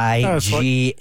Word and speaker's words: Sevigny: The only information I have, Paul, how would you say, Sevigny? – Sevigny. – It's like that Chloe.